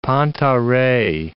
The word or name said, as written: panta rhei